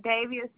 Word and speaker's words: Davia: Davia Silvers. – Can you spell it for me? – D-A-V-I-A.